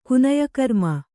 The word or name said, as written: ಕುನಯಕರ್ಮ